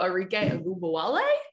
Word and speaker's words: Arike Ogunbowale: Yeah, we welcome in our guest, WNBA scoring champ from a season ago, Arike Ogunbowale. Did I say that right? – Actually, that was really good.